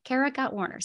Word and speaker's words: Kara Gott Warner: Kara Gott Warner.